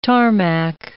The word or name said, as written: tarmac